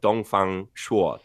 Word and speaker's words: Dongfang Shuo: And so one of the most famous stories is the story of Dongfang Shuo. Did I say that correctly? Yes. – Spot on this time.